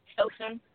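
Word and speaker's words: Filsan: My name is Solson. I'm calling from Columbus, Ohio. – Oh, right. Can you say your name again? I didn't quite catch that. – Solson. It's F-I-L-S-A-N.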